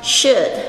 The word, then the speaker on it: should: 这边呢，我们来看一下 You should，这个就是说你应该 should S-H-O-U-L，注意一下它中间这个 L 是没有发音的， should 就是像我们中文讲的应该。